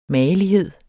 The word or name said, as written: magelighed